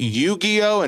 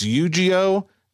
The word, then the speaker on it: Yu-Gi-Oh!: Apparently, I've been pronouncing it Yu-Gi-Oh! And it's Yu-Gi-Oh!